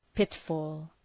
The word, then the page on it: pitfall: ‘pıt,fɔ:l